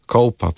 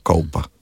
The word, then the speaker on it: Kolpa: ker sem rekel Kolpa, bi se takoj popravil, Kolpa pravijo, tam dol, na mene vodo, ne, ker lokalni izgovor, mislim, da moramo spoštovati in lahko jaz izgovorim Kolpa.